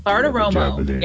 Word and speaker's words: Bartiromo: At number 10 on the people list, Maria Bartiromo. Do I pronounce that right? I never did. – Bartiromo. Big exit over at Fox Business.